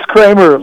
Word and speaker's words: Kremer: First of all, is it pronounced Kremer or Kramer? – It's Kramer, like Seinfeld.